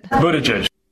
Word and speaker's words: Buttigieg: Buttigieg.